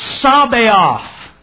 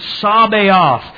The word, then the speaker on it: Sabaoth: But we have here Sabaoth. That's how you pronounce it. Sabaoth.